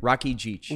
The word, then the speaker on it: Rakidzich: How do you say it? – Rakidzich. – Rakidzich. Sean Rakidzich. Right? – Yep.